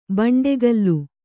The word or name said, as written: ಬಂಡೆಕಲ್ಲು